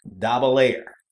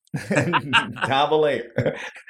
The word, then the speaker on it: Dobbelaere: And also for our listeners, his last name is pronounced Dabalayer. Dabalayer.